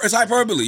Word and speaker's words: hyperbole: I always knew how to pronounce it, but for whatever reason, I said hyperbole. – You were like, shit, did Elon Musk invent something? – It's hyperbole. You know what I'm saying?